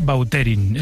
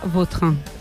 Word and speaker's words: Vautrin: Es que a mí me suena mejor Bauterín. Es como... – Da más fuerza, ¿no? – Más fuerza, más pronunciación. – Elegante. – La original francesa, Vautrin.